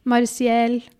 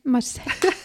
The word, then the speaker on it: Marseille: Jeg bare eh, Nei, jeg tror ikke det er sånn. Og så eh, sa jeg jeg tror det er Marseille eller noe sånt der.